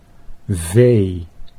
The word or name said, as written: vee